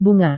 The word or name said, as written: bunga